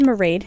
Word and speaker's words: Marade: My name is Marade, rhymes with parade, and I am one of the assistant music directors here at Brave New Radio.